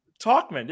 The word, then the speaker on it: Tauchman: Tauchman, is that right? – Tauchman, yeah.